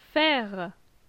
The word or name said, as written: Faire